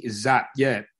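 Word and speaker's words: Zappe: I like the fact that you snuck that little stat in there for everyone out there that's wondering, who's, is it Zappy? How do you say his last name? Bailey Zapp? – Bailey Zapp, yeah.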